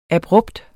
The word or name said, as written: abrupt